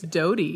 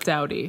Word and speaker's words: Doughty: It's Doughty. – Not Doughty. – No, not like, Doughty. – Okay, good. – Like bread dough t-shirt.